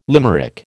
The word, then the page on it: limerick: American English